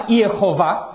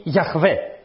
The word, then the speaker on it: Γιαχβέ: η έρευνα κλείνει ως πιο σωστό στην μορφή Γιαχβέ για την προφορά του ονόματος αυτού, αλλά πάλι δεν ξέρουμε.